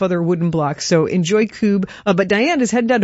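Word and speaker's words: Kubb: I'm told it is cube.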